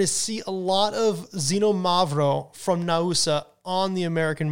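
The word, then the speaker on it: Xinomavro: And by the way, when you're looking for Xenomavro in the wine shop, just know it's not spelled with a Z. It's X-I-N-O-M-A-V-R-O, Xenomavro, which means acid black, because it has a very high acidity.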